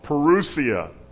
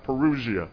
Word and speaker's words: parousia: All right, so the word Advent in the New Testament is actually a word called parousia. That's how it's pronounced, parousia.